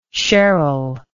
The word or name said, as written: sherrill